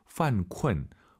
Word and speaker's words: 犯困: 犯困犯困 이 발음을 잘하셔야 됩니다. 쿤쿤 하지 마시고 유엔 사이에 어 들어가서 다시 한번 犯困.